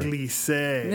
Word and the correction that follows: giclée: No, it's a giclée. – A giclée.